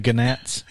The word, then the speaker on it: gnats: Well, the Gnats? – Yeah. The G is silent, yeah.